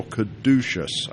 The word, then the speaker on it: Caduceus: This one is your Caduceus, I think I pronounced that right. – Correct, yep. You got it right the first try.